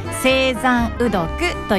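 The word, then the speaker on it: 青山雨読: これどんな文字を書くかと言いますと、晴れる、山、雨、読むと書いて青山うどくというね、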